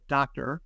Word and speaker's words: Doktar: The first is called Orbit, and Orbit is an AI-driven field scouting app that was developed by a startup called Doctor, and that's spelled D-O-K-T-A-R.